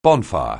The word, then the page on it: bonfire: anglais